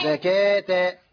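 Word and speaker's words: زكوٰة: وصلاة وزكاة، ملاحظة أن الواو فوقها ألف صغيرة. – نعم الألف هو الذي ينطق، ولكن الألف ينطق والواو لا تنطق في زكاة.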